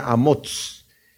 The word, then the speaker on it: Amoz: El Amós, el padre de, de Isaías, que el Amós profeta, de hecho, el padre de Isaías se pronuncia Amoz.